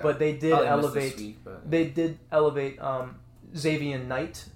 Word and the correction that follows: Zonovan Knight: Zonovan Knight. – Excuse me if I mispronounced your name, bro.